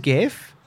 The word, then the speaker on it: GIF: GIF is GIF. – With a hard G.